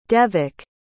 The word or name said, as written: Devak